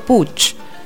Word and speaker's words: Puig: Escuchábamos recién la voz de Manuel Puig. O Puig o Puch. ¿Cómo se dice? – ¿Cuántas variantes? No sé, según el gallego de a fondo se dice Puch.